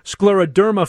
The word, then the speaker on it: scleroderma: Let me introduce the executive director of the Scleroderma Foundation of Greater Chicago. Am I saying it right? Am I getting anywhere close to the correct pronunciation? – that was very close and very correct. Right. Scleroderma.